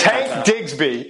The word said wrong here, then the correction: Bigsby: Uh, Tank Tank Bigsby.